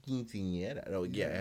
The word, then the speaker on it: quinceañera: So, you're going to Dallas in April, and I want to know about the origins of the quinceañera.